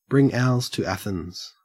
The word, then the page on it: bring owls to Athens: /bɹɪŋ ˈaʊlz tu ˈæ.θɪnz/